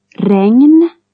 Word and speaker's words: regn: GN after a vowel is like NG plus N. – Ragnar. Regn.